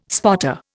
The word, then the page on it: spotter: Indian